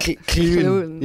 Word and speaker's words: Llewyn: Well, you're lucky you don't have any, like, sort of Welsh people calling it Llewyn. Yeah. One guy did. I thought that was pretty cool. – Wow, yeah. Obviously, that's how you would pronounce it in Welsh.